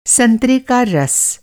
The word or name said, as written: संतरा